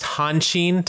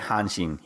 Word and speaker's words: Hanshin: And it's pronounced Hanshin Tigers. I want to make sure I'm saying it correctly. – Yeah, that's right. Hanshin.